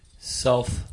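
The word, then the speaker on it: Selph: It's a S-E-L-P-H. How do you pronounce that, Doc? – Self. – Self.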